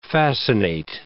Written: fascinate

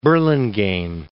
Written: Burlingame

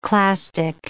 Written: clastic

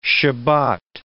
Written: Shebat

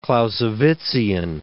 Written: Clausewitzian

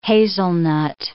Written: hazelnut

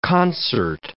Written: concert

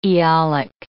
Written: Aeolic

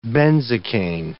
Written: benzocaine

